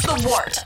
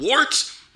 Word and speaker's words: The Wort: This is The Wort on Govs Radio. – It is The Wort. Not wart, not fort, not short. The Wort.